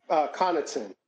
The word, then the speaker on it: Connaughton: Connaughton? – Connaughton.